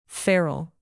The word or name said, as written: feral